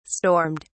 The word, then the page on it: stormed: British English